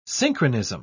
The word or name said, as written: synchronism